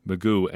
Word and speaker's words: McGough: Didn't look like they were going to make any moves there for a while, but they do indeed add a fourth quarterback, Magoo. And his name is pronounced Magoo, though it's spelled M-C-G-O-U-G-H. Take that pronunciation for whatever it's worth.